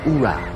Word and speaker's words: oorah: No, the men's department of the Navy, the Marines, and I always say, oorah. – Oorah, then. – More emphasis on the ooh first symbol. Oorah.